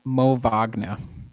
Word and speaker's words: Wagner: So yes, the W in German is pronounced like a V, so it'd be Mo Wagner would be the pronunciation.